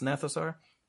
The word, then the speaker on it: Gnathosaur: The Gnathosaur, that's with a G. The Gnathosaur, you know, the G is silent, so it's Gnathosaur. – Yeah, like the Gnat.